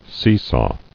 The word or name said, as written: Seesaw